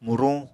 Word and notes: muruñ: Apatani

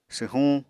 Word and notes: swhuñ: apatani

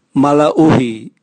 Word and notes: malauhi: Raga

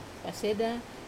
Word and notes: paseda: wamesa

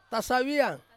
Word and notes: saviang: Ansus

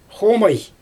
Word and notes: xomaj: Xyzyl

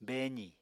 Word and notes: beezì: Apatani

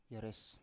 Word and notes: yeres: Biak

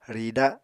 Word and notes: rida: Apatani

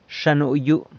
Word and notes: Xa nuyu: sanlucasquiavini

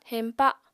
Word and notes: heñpa: Apatani

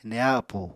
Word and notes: neha appú: Apatani

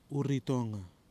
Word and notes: URITONGA: kwamera

